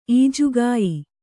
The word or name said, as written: ಈಜುಗಾಯಿ